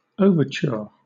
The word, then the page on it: overture: British English